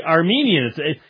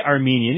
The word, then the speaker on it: Arminian: Yes, the Arminians. You try to finally say Armenian, you can't even say it right.